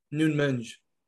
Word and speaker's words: Nunes: Nunes. – Yeah. Mm-hmm. – That's actually how you say it.